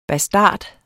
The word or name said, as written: BASTARDERNES